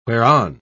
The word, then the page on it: whereon: British English